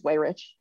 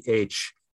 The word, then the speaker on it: Weyrich: I don't know how he, is it Weyrich? How does he pronounce his last name? Do you know? – I don't know. I think it's Weyrich. – Okay, so it's Weyrich.